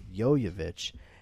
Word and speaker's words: Jovovich: She was on Last Call with Carson Daly years ago, and I had always said Mila Jovovich at that point, and she straight up said to him, like, that's not how my last name is pronounced. It's Jovovich. And he was like, oh, really? And she said, yeah, the best way to remember it is just say, yo, you bitch.